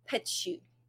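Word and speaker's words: Petiot: His name is Marcel Petchu, which I went and looked up the pronunciations of all this shit so I could tell it to you accurately.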